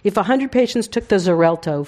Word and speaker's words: Xarelto: It's Zalexto. It's one of those things where they marketing wanted to have an X and a Z because those make medicines very effective. Mm. So they have an X and a Z in the same name, and I can't pronounce it. But anyway, it's Xarelto.